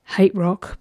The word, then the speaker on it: HTRK: All right, next up I've got a band called, pronounced Hate Rock, but it's just spelt H-T-R-K.